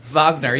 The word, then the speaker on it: Wagner: Wagner, yeah, exactly. – Oh, there's the W getting pronounced as a V.